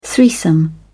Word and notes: threesome: British English

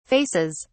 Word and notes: faces: British English

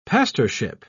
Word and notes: pastorship: British English

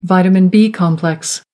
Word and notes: vitamin b complex: American English

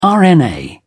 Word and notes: rna: British English

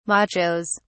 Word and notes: machos: British English

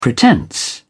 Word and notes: pretence: British English

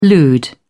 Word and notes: lewd: British English

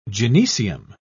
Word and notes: gynoecium: British English

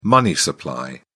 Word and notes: money supply: British English

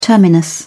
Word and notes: terminus: British English

